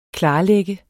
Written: KLARLAGT

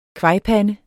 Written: kvajpande

